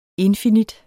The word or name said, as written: infinit